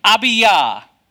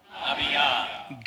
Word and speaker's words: Abijah: Abiyah. – Good. This is how you pronounce the priestly division that Zechariah is from. It's Abiyah. It looks like Abijah, but there are no J's in Hebrew or Greek.